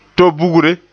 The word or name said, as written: to-bugre